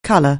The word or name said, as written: colour